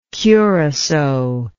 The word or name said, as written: curassow